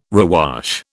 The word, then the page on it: rewash: British English